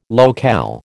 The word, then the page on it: locale: American English